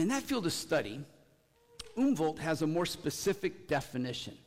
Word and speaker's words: Umwelt: It's spelled U-M-W-E-L-T, which I assume is pronounced Umwelt. Does that sound right for you German speakers out there? Umwelt, I'm getting some thumbs up.